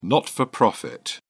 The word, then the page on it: not-for-profit: British English